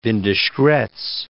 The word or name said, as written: Windischgratz